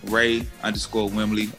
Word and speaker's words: Ray Wimley: It's Ray, R-A-Y, Wimley, W-I-M-L-E-Y. It's pronounced Wim, like on a whim, and Lee, like Bruce Lee.